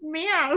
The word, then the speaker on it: Mia: they pronounced my name wrong. And I remember all three of us were like, "It's pronounced Mia."